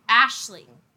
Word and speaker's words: Ashling: Ashling. – Ashley Fay. – I believe I'm saying it right, yeah. It's an Irish name.